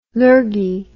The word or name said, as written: lurgy